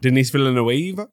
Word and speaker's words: Denis Villeneuve: Written by Frank Herbert oh so long ago, directed by Denis Villeneuve, and wait, say his name again. – Denis Villeneuve. That's accurate. – Denis Villeneuve.